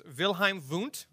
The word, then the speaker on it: Wilhelm Wundt: His name was Wilhelm Wundt, that's with W's, but pronounced as V's, taught at the University of Leipzig in Germany, and he introduced the first psychological scientific laboratory.